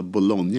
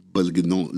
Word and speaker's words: Bologna: Jag hörde någon som uttala Bologna igår, det var väldigt kul. De lyckades liksom Belgnon.